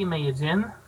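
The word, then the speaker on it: Medellín: Now, that's an interesting town, because when you look at it, it's actually spelled M-E-D-E-L-L-I-N, but it's almost pronounced inverse, like Medellin.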